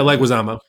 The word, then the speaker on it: Leguizamo: Leguizamo? – Yeah, Leguizamo. – I really can't pronounce anybody's names, can I? – No, you're usually pretty good. His is weird. Like, you said it right phonetically.